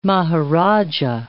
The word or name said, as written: maharaja